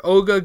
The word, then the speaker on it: Oga: Oga. O-G-A. O-G-A. That's how I would pronounce it, right? Oga? – I guess so. I can't imagine how else.